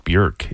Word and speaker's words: Björk: Actually, her name is pronounced Björk if you're from Iceland.